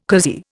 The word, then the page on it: cozy: Indian